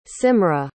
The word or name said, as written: simra